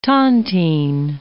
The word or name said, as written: tontine